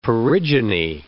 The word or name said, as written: perigyny